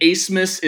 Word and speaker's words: Abmas: Kevin O'Banner, Max Asmus, and Asmus is spelled A-B-M-A-S. Not only is there a silent B, it also just completely, there's an S thrown in there for some reason, too.